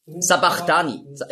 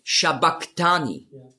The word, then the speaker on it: Sabachthani: Sabachthani lesen viele. Aber das heißt eigentlich auf Aramäisch ausgesprochen ist es Shabachthani. Und das ist das CH, das Chi, ist im Griechischen als K ausgesprochen worden. Aber das S steht eben für den Sch-Laut.